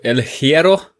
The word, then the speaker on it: El Hierro: es war ja, ja die, die, das Finale der Golden Trail Series auf El Hierro.